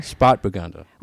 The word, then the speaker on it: Spätburgunder: Spätburgunder.